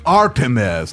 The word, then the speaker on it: Artemis: Vamos com o projeto Artemis! – Artemis, tem a pronúncia também. A gente esqueceu do a gente tá tão acostumado a falar Artemis, né? O desafio é Artemis.